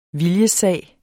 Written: viljessag

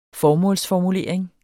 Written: formålsformulering

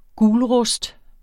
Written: gulrust

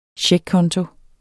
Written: CHECKKONTO

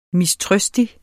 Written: mistrøstig

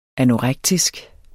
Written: anorektisk

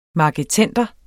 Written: marketender

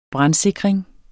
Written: brandsikring

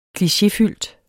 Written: klichéfyldt

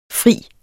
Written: frier

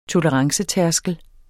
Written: tolerancetærskel

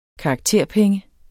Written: karakterpenge

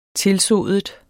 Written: tilsodet

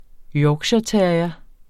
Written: yorkshireterrier

